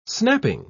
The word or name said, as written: snapping